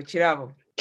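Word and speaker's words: Kirabo: Again, it's like Chinto, Chirabo. – K is Ch. – Chirabo. K-I. So you know the musician Chiwanuka? – Yeah. Oh! – He's not Kiwanuka.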